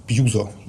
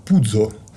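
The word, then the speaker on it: Puzo: Марио, он не просто Пузо, он по-итальянски Марио Пудзо. – Пудзо. – Да, или Пуццо, то есть тут как по-разному. Ну, Марио Пудзо.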